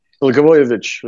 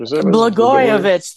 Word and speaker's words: Blagojevich: Blagojevich. – Blagojevich.